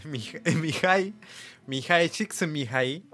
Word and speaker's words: Mihaly: De hecho, inclusive el nombre lo están diciendo mal, porque no es Mihaly. Es Mihai, Mihai, así, Mihai, Mihai.